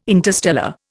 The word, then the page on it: interstellar: Indian